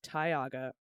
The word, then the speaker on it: Tioga: I totally don't want to be that person, but Tioga County is pronounced Tioga, rhymes with toga.